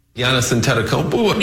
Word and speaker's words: Giannis Antetokounmpo: Giannis Antetokounmpo. – The NBA player Giannis Antetokounmpo caught my eye.